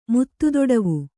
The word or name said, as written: ಮುತ್ತುದೊಡವು